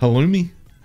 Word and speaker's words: Halloumi: Halloumi. Halloumi. Halloumi. – Is it spelled like salami with an H? – No, it's H-A-L-L-O-U-M-I.